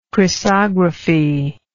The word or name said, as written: chrysography